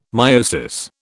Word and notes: meiosis: British English

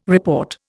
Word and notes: report: Indian

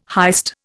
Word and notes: heist: Indian

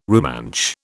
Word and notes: Rumansh: British English

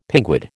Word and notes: pinguid: American English

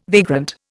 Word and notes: vagrant: Indian

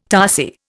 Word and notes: tarsier: Indian

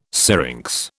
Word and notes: syrinx: British English

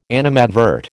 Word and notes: animadvert: American English

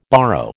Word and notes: borrow: American English

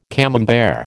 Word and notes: Camembert: American English

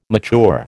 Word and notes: mature: American English

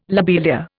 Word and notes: lobelia: Indian

